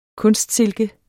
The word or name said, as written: kunstsilke